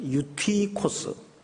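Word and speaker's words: 유티코스 (Εὔτυχος): 이 청년 이름이 한글 성경에는 유두고라고 되어 있는데 그 원 발음으로 읽으면 유티코스입니다. 유티코스.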